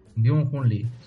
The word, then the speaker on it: Byung-Hun Lee: Byung-Hun Lee. – Byung-Hun Lee, sí.